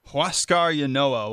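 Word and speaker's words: Huascar Ynoa: They're 20-20 after the loss tonight, and they'll go back at trying to salvage a game in the series tomorrow as how do you pronounce his name for Atlanta? – Huascar Yanoa will be pitching for the Atlanta Braves tomorrow, but he's probably their hottest pitcher, currently 4-1 in the year.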